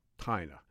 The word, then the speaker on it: Tina: And his friends called him, hey, little Tina, you know, because tiny. So it became Tina. Anyway, that's how you pronounce his name.